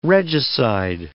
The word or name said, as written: regicide